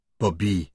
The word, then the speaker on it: Bobby: She pronounced his name with equal emphasis on each syllable. – "Bobby."